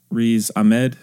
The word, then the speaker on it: Riz Ahmed: Okay, so Venom was released in 2018 and stars, or is directed by Ruben Fleischer, stars Tom Hardy, Michelle Williams, Riz Ahmed, is that how you say it? – That sounds right.